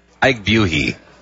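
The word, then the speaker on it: Buehi: Ike Buhi, I believe is how you say his name.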